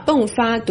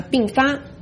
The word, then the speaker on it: 迸发: 今天为了纪念五四运动 100周年，习近平特意在 4月30日提前致辞，结果把老人赡养错读成老人瞻仰，还有人指出他把迸发读成了并发。